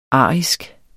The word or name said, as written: arisk